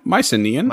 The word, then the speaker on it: Mycenaean: Mycenaean. – Yeah, Mycenaean. I think you said that right.